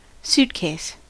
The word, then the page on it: suitcase: American English